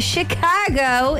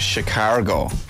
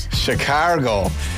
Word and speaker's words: Chicago: You know it's pronounced Chicago. – Sorry. Chicago, is it not? – Chicago.